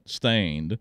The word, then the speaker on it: Staind: He's the lead singer, founding member of the new NU, not N-E-W, NU-metal, NU, new metal, band Stained, S-T-A-I-N-D. That's how they spell it, Stained.